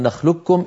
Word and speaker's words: نخلقكم: لذلك لما نقرأ لرواية حفص عن عاصم فإننا ندغم القاف في الكاف من قوله تعالى ألم نخلقكم إدغاما كاملا، إدغاما محضا ويكون نطقها بكاف مشددة.